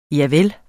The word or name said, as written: javel